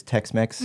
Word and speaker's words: TXMX: And it's, we spell it T-X-M-X, but it's Tex-Mex, mm-hmm.